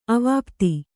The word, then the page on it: ಅವಾಪ್ತ: avāpta